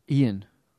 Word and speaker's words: Eoin: E-O-I-N or E-O-G-H-A-N. Both the same pronunciation, just 2 different spellings. What do you think? – I'm going to go Ian. – E-O-I-N. Ian.